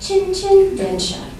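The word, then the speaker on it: Chin-Chin Densha (チンチン電車): Now, that's like a trolley car, and little did I know that you have to say it like Chin-Chin Densha, like the sound.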